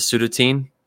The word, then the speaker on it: Sudatine: Planet that I've always pronounced as Sudatine? – Yep. – Yes. – Words are hard. – It rhymes with Ovaltine.